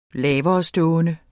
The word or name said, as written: laverestående